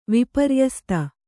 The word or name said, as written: ವಿಪರ್ಯಸ್ತ